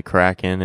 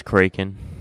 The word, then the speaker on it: Kraken: I think most Americans, although there might be some regional exceptions, I think most Americans say Kraken, and most of the rest of the world says Kraken.